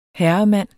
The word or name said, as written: HERREMANDENS